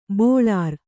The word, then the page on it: ಬೆಳರ್: beḷar